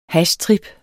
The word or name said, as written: hashtrip